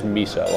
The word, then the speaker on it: Meso: And by the way, it's actually not Meso. I've called him Meso for years, but it is Miso, like miso soup, even though it's spelled Meso.